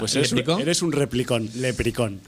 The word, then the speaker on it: leprechaun: Se pronuncia sencillamente leprecón. – Leprecón. – Sí. – Vale. – Ni leprecaun, ni leprechaun, evidentemente no. – Leprecón. – Leprecón. – Como replicón, pero leprecón.